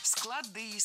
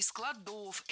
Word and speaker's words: склады: Поэтому «склады», «складов». Но есть еще и слово «склад» со значением «слог», сочетание звуков. И у него как раз при склонении ударение переносится на окончание. Читать по складам, но развозить товары по складам.